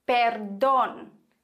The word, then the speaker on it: perdón: ganz arg wichtig hier, betont wird in einem Wort mit Akzent immer die Silbe, auf der der Akzent liegt. Beispiele für Wörter mit Akzent, por ejemplo, perdón, perdón o teléfono, teléfono for no. Perdon bedeutet Entschuldigung und wir haben hier einen Akzent auf dem O, also müssen wir auch unbedingt diese Silbe betonen.